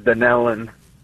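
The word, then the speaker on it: Dunnellon: I'm going to go with Dunelon, Florida, watching on YouTube. I hope I said the town right. I have not heard of that town before. – No, actually, it's pronounced Dunelon.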